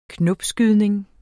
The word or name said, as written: knopskydning